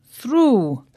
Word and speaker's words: through: O-U-G-H, and through, spelt T-H-R-O-U-G-H. Now, the O-U-G-H in although sounds like O, although. – And in through, it sounds like ooh, through.